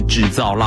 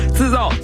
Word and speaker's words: 自造: 哎，我没有念错哈，我没有把它念成平翘舌部分啊，是自造，不是制造浪漫。自造自己的自，造是躁动的躁。